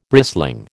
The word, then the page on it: brisling: American English